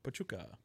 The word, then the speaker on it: Pachuca: Pachuca.